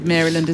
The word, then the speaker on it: Maryland: Catherine says, as a lifetime Marylander, I'd like to suggest a phonetic spelling of Maryland as Marilyn plus a D.